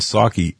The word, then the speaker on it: Psaki: And you've seen Jen Psaki, the P is silent, as is the president, the P in president with Biden. You've seen Psaki attempt to save the narrative,